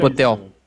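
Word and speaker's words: Pöltl: Jacob. – Potel. – Ah, o. Potel. Potel. Potel. Isso. Isso, ele também tá nesse bolo aí do top 10 aí.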